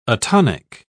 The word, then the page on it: atonic: British English